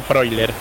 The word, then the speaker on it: Freuler: Bueno, ya aprendí. Freuler. Se pronuncia Freuler.